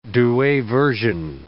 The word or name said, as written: Douay Version